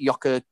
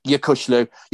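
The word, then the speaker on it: Yokuşlu: Your new lad in midfield, is it Yoka, how do you say his name? – Yakushlu. – Yakushlu added a different dimension to you.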